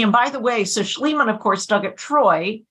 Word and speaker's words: Schliemann: Schliemann.